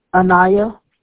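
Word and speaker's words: Aniya: A-N-Y, Amy? – No, A-N-I-Y. Like igloo. – How do you pronounce that? – Anaya. – Okay, and your last name? – Jacobs. J-A-C-O-B-S.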